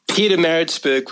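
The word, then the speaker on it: Pietermaritzburg: I can't pronounce the name of the city that it's located in. – Peter Maritzburg would do the job, Steve.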